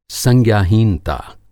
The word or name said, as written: संज्ञाहीनता